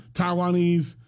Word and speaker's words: Taiwanese: Is that the proper way to say Taiwan? – Yes. – People? Taiwanese.